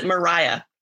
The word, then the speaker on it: Maria: Her name is spelled Maria, but they pronounce it Mariah.